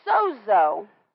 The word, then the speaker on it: sozo: In the Greek, that word, the way they pronounce it, is sowed, S-O-E-D, sowed, zo, Z-O. But it's actually spelled S-O-Z-O. Do what? – It's S-O-D-E, not S-O-E-D.